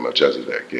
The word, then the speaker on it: Melchizedek: That's how you say it? – Yeah, Melchizedek, yeah.